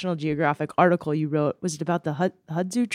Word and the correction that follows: Hadza: tribe or how do I say that? Yeah, the Hadza tribe the Hadza of uh, tribe.